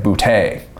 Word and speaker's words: Boutte: if you say it like real Louisiana, it's like Boutte. Like that's that's like you hear Ed Orgeron say that, and then you okay.